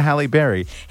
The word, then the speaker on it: Halle Berry: until he got to Halle Berry.